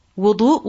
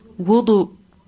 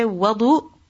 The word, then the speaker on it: Wudu: So what's the correct pronunciation next time you want to go do ablution? What will you say? Wudu. If you say wadu, then that is incorrect pronunciation.